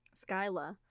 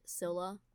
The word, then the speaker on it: Scylla: He felt self-righteous and super narcissistic, and he went for Skyla, or not, nope, sorry, that's a super bad pronunciation. When I was looking up pronunciations, I found one that pronounced her name like that, Skyla, and then another one that pronounced it Scylla, which is what I believe the correct pronunciation is.